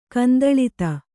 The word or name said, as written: ಕಂದಳಿತ